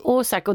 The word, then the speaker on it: Ozark: Och vad heter den då, hur uttalar man den? Osark. – Eh. Ozark